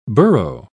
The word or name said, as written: burrow